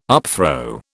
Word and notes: upthrow: British English

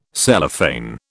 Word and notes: Cellophane: British English

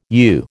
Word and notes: you: American English

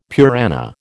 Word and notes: Purana: American English

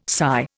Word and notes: sigh: Indian

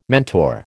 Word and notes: mentor: American English

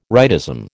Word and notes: rightism: American English

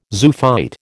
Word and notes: zoophyte: American English

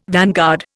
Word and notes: vanguard: Indian

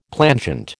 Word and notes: plangent: American English

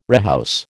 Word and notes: rehouse: American English

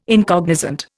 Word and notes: incognizant: Indian

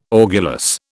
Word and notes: orgulous: British English

